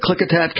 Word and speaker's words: Klickitat: Is it Klickitat County in Washington? Am I saying that right? – Yes.